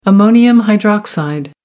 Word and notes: ammonium hydroxide: American English

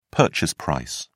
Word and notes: purchase price: British English

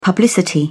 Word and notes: publicity: British English

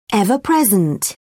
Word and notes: ever-present: British English

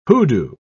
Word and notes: hoodoo: British English

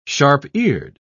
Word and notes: sharp-eared: British English

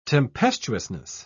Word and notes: tempestuousness: British English

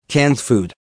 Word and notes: canned food: American English

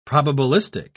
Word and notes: probabilistic: British English

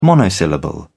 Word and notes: monosyllable: British English

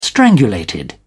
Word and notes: strangulated: British English

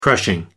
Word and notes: crushing: American English

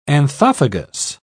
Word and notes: anthophagous: British English

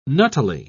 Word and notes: nuttily: British English

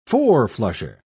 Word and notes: four-flusher: British English

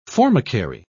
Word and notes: formicary: British English

